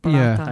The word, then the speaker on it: burrata: That was a stunning pronunciation of Baratta.